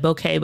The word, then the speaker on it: bokeh: Yeah, I think the correct pronunciation is like bokeh, but I say bokeh too.